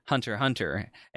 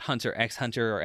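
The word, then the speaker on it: Hunter × Hunter: Well, I mean, my current stance, of course, is that Hunter Hunter is pronounced Hunter Hunter and not Hunter X Hunter or Hunter Cross Hunter.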